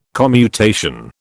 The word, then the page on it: commutation: British English